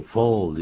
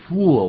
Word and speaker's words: full: 这句话的英文呢，就要用 full 这个字，可不要念成 I'm full，那不得了，那是 I'm a fool，我是一个呆子了啊。